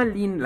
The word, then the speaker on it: Lean: o sea, pero se escribe Lean. – Lean. Como, mensaje de cierta eh, tienda de libros de aquí de México. – Y entendí. Lean. Amarilla. Correcto. Pero no. Se, se, pronuncia Lin.